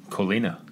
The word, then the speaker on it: Collina: Gotta say your last name for me. – Collina. – Collina. – There you go.